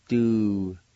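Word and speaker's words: Du-le-na (ᏚᎴᏅ): The N will have a nasal sound to it. Du-le-na. Again, arose. Du-le-na. Du-le-na.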